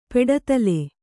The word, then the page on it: ಪೆಡದಲೆ: peḍadale